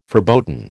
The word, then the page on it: verboten: American English